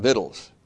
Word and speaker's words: victuals: It's spelled victuals, but if you ever saw the Clampets, the Beverly Hillbillies, you know how to pronounce this, because Granny always called them victuals.